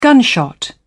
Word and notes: gunshot: British English

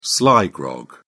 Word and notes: sly grog: British English

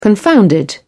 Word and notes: confounded: British English

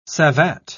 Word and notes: savate: British English

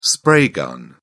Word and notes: spray gun: British English

